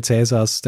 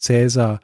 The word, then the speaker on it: Cäsar: Und dort ist euch vielleicht erklärt worden, dass zur Zeit Cäsars der Name Cäsar eigentlich ähnlich wie Kaiser ausgesprochen worden ist.